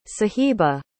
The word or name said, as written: sahiba